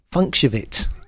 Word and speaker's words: Funkshovit: It's Funkshivit. Funkshivit. it's the way it's spelt.